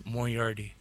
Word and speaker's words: Moriarty: So I was like, how do you say it again? – Dr. Moriarty. – Moriarty. – Hey, you'll get it, man. – Moriarty. – You'll get it. – Yeah, you said it.